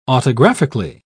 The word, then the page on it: autographically: British English